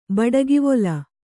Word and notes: ಬಡಗಿವೊಲ: baḍagivola

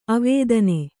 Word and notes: ಅವದಾನ: avadāna

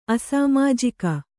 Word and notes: ಅಸಾಮಾಜಿಕ: asāmājika

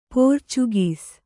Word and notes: ಪೋರ್ಚುಗೀಸ್: pōrcugīs

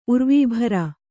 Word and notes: ಉರ್ವೀಭರ: urvībhara

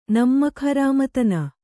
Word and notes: ನಿಮ್ಮಕುಹರಾಮತನ: nimmaku harāmatana

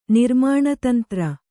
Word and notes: ನಿರ್ಮಾಣತಂತ್ರ: nirmāṇa tantra

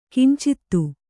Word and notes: ಕಿಂಚಿತ್ತು: kannada

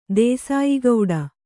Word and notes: ದೇಸಾಯಿಗೌಡ: dēsāyi gauḍa

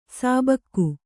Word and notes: ಸಿಬಿಕೆ: Kannada

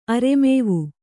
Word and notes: ಅರೆಮೇವು: aremēvu

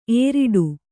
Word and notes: ಏರಿಡು: ēriḍu

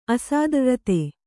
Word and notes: ಅಸಾದ್ರ್ರ್ರತೆ: asārdrate

